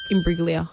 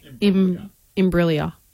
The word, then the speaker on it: Imbruglia: Soft G. Soft G. – Imbruglia. – Soft G. – Imbruglia. – Bang. – Imbruglia. Natalie Imbruglia.